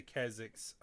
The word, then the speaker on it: Kezix: I said we're gonna cut to Kezix. Did I say it right again? – Sounds good. Kezix, yep. – Hey. As we cut to Kezix, it was only about 30 minutes outside of town from what the Yellow Jacket told you.